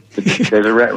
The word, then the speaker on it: Deseret: My apologies, Kenny. I pronounced that Deseret. – Okay. – Deseret Ranch, yes.